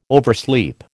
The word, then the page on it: oversleep: American English